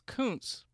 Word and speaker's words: Kuncz: It's Kuntz, but it's spelled K-U-N-C-Z, which can often be mistaken for a part of the female anatomy.